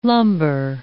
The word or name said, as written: lumbar